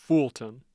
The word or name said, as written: Fulton